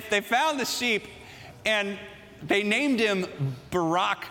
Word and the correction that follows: Baarack: Actually, I said it wrong. They actually named it this way Baarack. That is the sheep's name.